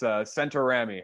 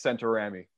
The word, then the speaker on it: Centaurami: It's Italian. I know some people have some trouble pronouncing it. It's Centaurami, Alex Centaurami.